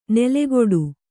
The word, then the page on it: ನೆಲೆಗೊಡು: nelegoḍu